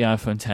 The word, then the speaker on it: iPhone X: But no, it is the iPhone X, and I will call it the iPhone X, and I will endeavor to call it the iPhone X for as long as the iPhone X exists, because that's the correct pronunciation of the product.